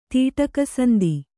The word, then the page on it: ತೀಟಕಸಂದಿ: tīṭaka sandi